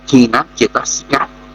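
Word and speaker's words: thīnacetasika: Kính thưa quý vị, đối với cái thực tính pháp thứ 30, hôn trầm tâm sở, tiếng Bali đọc là thi nát chê tá sĩ cá.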